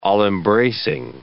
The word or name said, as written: all-embracing